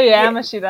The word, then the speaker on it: Yamashita: Still Sarah Yamashita. I do like Yama, a lower Yama. A instead of an A.